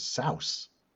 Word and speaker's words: Seuss: Now, Dr. Seuss is actually how he would how he said it was supposed to be said was souse, but we all took it as "seuss."